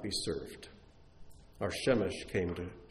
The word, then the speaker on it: Shemesh: But this word, Shamash, can also be pronounced Shemesh. And when it's pronounced Shemesh, it means sun, S-U-N, the source of all light on our world.